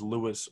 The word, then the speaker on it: Luis: You know, I feel like because of, like, you know, my name is Luis Ortiz, and it's not spelled like I have on screen.